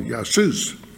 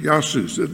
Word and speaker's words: Iesous: You can look at manuscripts in Greek, Jesus is spelled I-E-S-O-U-S, and the I sounds like a Y. It's called Yasus. very similar to the Spanish Jesus, in Greek Yasus.